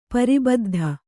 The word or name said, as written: ಪರಿಬದ್ಧ